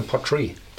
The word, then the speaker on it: Port Ruighe: Da gibt es also auch eine zweite Aussprache, Portree.